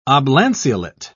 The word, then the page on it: oblanceolate: British English